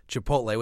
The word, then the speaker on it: Chipotle: Chipotle, which I assume that since you're saying it the same way I am, we're saying it correctly.